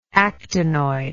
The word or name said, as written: actinoid